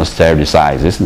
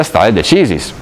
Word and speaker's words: stare decisis: Allora, in quel sistema vige il principio dello stare decisis, espressione latina che in America pronunciano stare decisis naturalmente, ma resta stare decisis, e cioè il sistema del precedente, che è considerato vincolante.